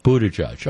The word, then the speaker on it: Buttigieg: I've always gone with boot a judge. – Buddha judge.